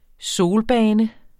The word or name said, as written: solbane